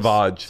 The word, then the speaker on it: Sauvage: Savage? Is it called? – Sauvage. – Sauvage? Who's, that's, I don't know. They sent it to me. What is it? Dior. Okay. It's Sauvage? – Swouge.